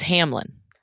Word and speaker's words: Hamline: In my portion of the episode where I talked about a controversy at a St. Paul university, I mispronounced the university Hamline. It is Hamline. – Right. But that is it is spelled Hamline. So it's an honest mistake, but yes, Hamline.